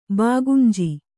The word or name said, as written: ಬಾಗುಂಜಿ